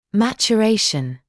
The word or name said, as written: maturation